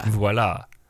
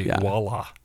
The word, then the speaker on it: voila: It's supposed to be voila, right? Like, you do pronounce the V. – Yeah. – Voila. – Yeah, yeah. – My dad used to always say voila. Voila. With a straight W.